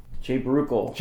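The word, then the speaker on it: Jay Baruchel: Today we're discussing the trifecta of This is the End, starring Seth Rogen, James Franco, Jonah Hill, Jay Bruckel.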